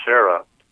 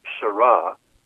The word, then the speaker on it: Sarah: Let me say, first of all, that I also used to pronounce his name Serra, but I found out it's actually pronounced Sarah, which I like because it rhymes with hurrah.